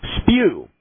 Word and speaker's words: spue: But in the King James Bible, it's spelled S-P-U-E, spew. When you look right. – that word up, it means vomit.